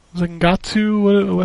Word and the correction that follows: Zengetsu: how do you say? – Yeah, Zengetsu.